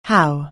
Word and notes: how: anglais

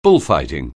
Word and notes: bullfighting: anglais

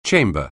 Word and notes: chamber: anglais

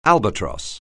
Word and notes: albatross: English

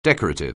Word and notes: decorative: anglais

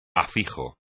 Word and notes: afijo: espagnol

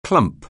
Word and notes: clump: anglais

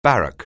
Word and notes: barrack: anglais

